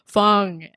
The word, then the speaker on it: Fung: fung f-u-n-g like I, yeah.